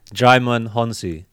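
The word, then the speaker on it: Djimon Hounsou: Jaimon Hounsou.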